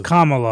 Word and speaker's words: Kamala: It's Kamala. I'm not going to be. I learned it because if you mispronounce it, you're a racist. And so it's Kamala.